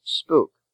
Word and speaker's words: spook: Also, although spook is in the look, took spelling family, it is pronounced spook.